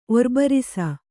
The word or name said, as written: ಒರ್ಬರಿಸ